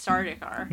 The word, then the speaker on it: Sardaukar: And your your pronunciation is pretty good. It's Sardaukar.